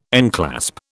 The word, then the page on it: enclasp: British English